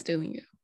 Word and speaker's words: Duolingo: I'm not sure if I will pronounce it right. Duolingo or Duolingo. – Oh yes, Duolingo.